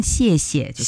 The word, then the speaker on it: 谢谢: 一个呢就是谢谢两个字，千万不要带着声调啊，要读轻声，谢谢就可以了。